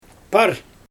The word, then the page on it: par: Xyzyl